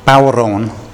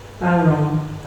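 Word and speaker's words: bodhrán: So, I think I understand that it's pronounced Bowron. Am I right? Would an Irish lady like to pronounce it? – Bowron.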